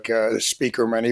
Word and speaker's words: Spieckerman: And yeah, my last name, it's phonetically correct, but has some extra letters in it, so people have to be careful to spell it. But it is pronounced like Spieckerman, even though it's not spelled the way people usually expect that to be spelled.